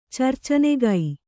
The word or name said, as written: ಚರ್ಚನೆಗೈ